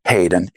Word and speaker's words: Hayden: Well, they can contact me directly through email, and it's D Hayden, H-A-Y-D-E-N, at Arthrito, because a lot of people say, how do you pronounce that?